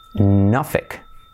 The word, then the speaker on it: Norfolk: Se pronuncia Norfolk.